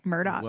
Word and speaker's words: Murdaugh: I guess his name's Alex Murdaugh, but he pronounces it Alec Murdoch.